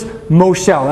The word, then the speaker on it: Moshel: The Hebrew word for ruler is Moshel. Everybody say Moshel. I would spell that M-O-S-H-E-L, Moshel.